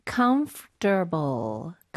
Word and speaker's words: comfortable: 근데 이거는 스펠링 정확하게 발음하는 것보다는 그냥 comf 약간 comf 보다는 conf로 생각하는 게 나아. comf 그다음에 바로 turble. – 아, comfortable. – 중간에 ore 없다고 생각하셔도 괜찮습니다.